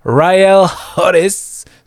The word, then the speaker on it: Ryle Hodges: Par Ryle Hodges sans doute. Euh, il faut le prononcer comme ça. Souvent, il y a une aspiration du H que j'ai bien du mal à, à mettre en anglais.